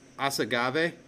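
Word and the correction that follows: Más Agave: did I say it right? – Oh, Masagave.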